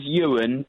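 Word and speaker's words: Uwin: This is Ewan, but with a U.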